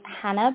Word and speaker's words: Jana: My name is Hannah, but it's spelled J-A-N-A to confuse everyone.